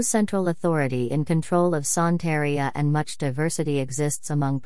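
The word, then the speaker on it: Santería: Santeria, Spanish pronunciation, Santeria, also known as Regla de Ocha,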